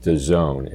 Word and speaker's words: DAZN: Well, most listeners have probably heard of the service pronounced DAZN. It's spelled capital D, capital A, capital Z, capital N, but pronounced DAZN.